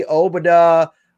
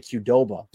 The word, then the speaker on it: Obada: So kind of like Qdoba. I keep wanting to say Qdoba, but it's Obada. Okay. – Obada.